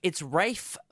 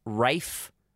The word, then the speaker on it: Ralph: So it's actually, I think technically it's pronounced Rafe. R-A-L-P-H is pronounced Rafe Fiennes. Not even S. Fine. And people just say it so quickly it comes out as Ray Fiennes. So everyone just says Ray, R-A-Y. But it's not. But it's actually spelled R-A-L-P-H.